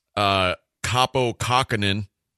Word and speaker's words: Kaapo Kakkonen: And one of my other decent goalies, I don't even know how to say his name, Kaapo Kakkonen. Is that close? – That's very close, yes. – Okay. – That's actually correct.